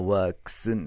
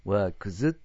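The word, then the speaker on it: works: デスクスになるんだからワークスになるわけ。ワークズとはならないわけ。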